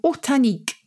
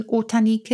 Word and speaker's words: ortanique: O-R-T-A-N-I-Q-U-E. – Oh, ortan. Ortan. What does ortan mean? Or orta, and the nique is on the end, like picnic.